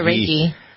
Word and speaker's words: Rakhi: This full moon is a merger of a supermoon and a blue moon in alignment with the Hindu festival of Rakhi Purnima. How does that do? Did I pronounce that correctly? Rakhi, Reiki, right. Maybe. It may be Reiki. Reiki, Rakhi. You say Reiki. – Yeah, Reiki.